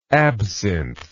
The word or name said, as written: absinthism